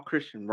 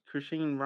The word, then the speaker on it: Chrisean: They're look, Chrisean Rock, obviously, she's Chrisean Rock. Yeah, I believe that's how you say her name.